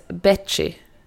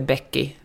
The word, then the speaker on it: Bechi: Jag läste Koko Hubaras Betchi. Jag tror den uttalas, det har jag tänkt på hela tiden. Jag tror den uttalas Betchi och inte Becky, utan Betchi. Det handlar om... – Jaha, det är B-E-C-H-I alltså.